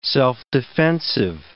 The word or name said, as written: self-defensive